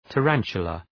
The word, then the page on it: tarantula: tə’ræntʃələ